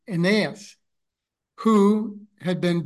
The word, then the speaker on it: Aeneas: So here I chose to pronounce the name like the A-E-N as like pain, as in pain, ain, and then naos, Aeneas. I'm not really sure if that's an accurate pronunciation, but anyway, beginning with chapter 9, verse 32.